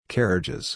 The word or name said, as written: carriages